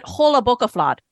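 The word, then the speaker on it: jólabókaflóð: So it's Jola Boca Flod. Is that how you pronounce it? Or is it Jola Boca Flod? – Well, both. Well done. Well done.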